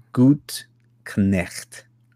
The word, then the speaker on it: Gutknecht: Now, here's your family name kind of threw us for a loop here, but he sent me a recording of how to actually pronounce his last name. And it's something like, see if this is right, Chris. Gutknecht, something like that.